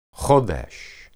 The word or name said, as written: hodesh